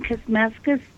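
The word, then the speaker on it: Kuzmeskus: I hope I'm pronouncing that right. – That's right. It's K-U-Z-M-E-S-K-U-S. And for people that want to get in touch with me, they can reach me on Facebook, Elaine Kuzmeskis, Spiritualist Medium.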